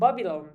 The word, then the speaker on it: Babylon: Ve bu kesinlikle Babylon diye okunmuyor. ba- Babylon diye okunuyor.